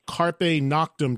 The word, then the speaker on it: Carpe Noctem: Of course, also the new Armored Saint live record, which is called Carpe Noctem. Did I say that right? – You did, I think, although I'm not a, you know, expert in Latin, but you sounded pretty good.